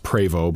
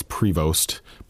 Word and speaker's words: Prevost: It's pronounced Prevost, but a lot of people think it's Prevost.